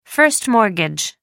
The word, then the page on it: first mortgage: American English